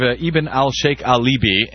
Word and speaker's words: Ibn al-Sheikh al-Libi: So very quickly, on this story of Ibn al-Sheikh al-Libi, and am I pronouncing it close to correctly there, by the way? – I think that sounds about right to me, actually.